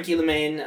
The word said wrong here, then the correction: Guillaume: There's no N. – Guillemain. Look, I can't pronounce his name. Guillaume.